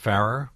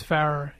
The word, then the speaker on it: Farrar: But his name is pronounced Farrar because It is. It's Farrar, yes.